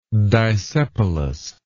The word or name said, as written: disepalous